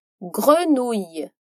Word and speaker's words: grenouille: Que se escreve G-R-E-N-O-U-I-2-L-E. Essa palavra é composta por duas sílabas. Grenouille. A primeira sílaba é um pouco difícil de pronunciar por causa da combinação entre as letras G-R-E. Como em grenier, sótão. Então, para pronunciar bem, você precisa sentir sua garganta vibrar.